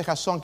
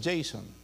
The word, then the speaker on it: Jasón: En inglés es Jason. ¿Ustedes lo pronuncian mal? No, en español se dice Jasón. Ahí tiene un acento en la O, ¿verdad? ¿Lo han visto? Jasón. En inglés es Jason, ¿ok?